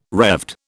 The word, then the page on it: Revd: British English